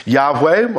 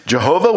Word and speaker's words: Yahweh: which we sometimes pronounce as Yahweh, or we'll sometimes say it as Jehovah, which is wrong. There's no J sound in Hebrew.